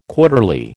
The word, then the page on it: quarterly: American English